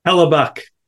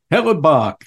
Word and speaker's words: Hellebuyck: I don't know if I said that right. Connor Hellebuyck. Say that again, please. – Hellebuyck. – Hellebuyck. There you go.